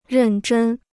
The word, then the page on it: 认真: rèn zhēn